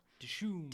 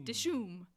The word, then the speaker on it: Dishoom: It looks like dish room, but take out the R. – Dishoom. – Dishoom.